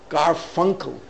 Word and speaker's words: Garfunkel: But there's one part in the book that Garfunkel, Simon and Garfunkel, am I pronouncing his name right? The Garfunkel.